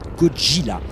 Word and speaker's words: Godzilla: Enfin, pour être complet, sachez que Godzilla se prononce en japonais Godzilla.